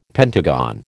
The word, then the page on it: pentagon: American English